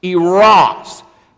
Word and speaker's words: eros: The first one is eros. Eros. It's spelled E-R-O-S.